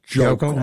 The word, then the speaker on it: Hochul: You are increasingly having a good shot at defeating Kathy Hochul. – Jokel. I think you said it wrong. It's Jokel.